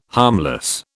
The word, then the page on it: harmless: British English